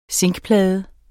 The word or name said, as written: zinkplade